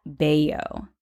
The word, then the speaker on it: Bello: We called this cat Bayo, spelled B-E-L-L-O, but pronounced Bayo, like the suave feline he was.